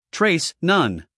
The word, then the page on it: trace: treɪs